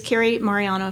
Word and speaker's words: Mariano: So if I'm around my normal friends, family, business, it is Kerri Mariano.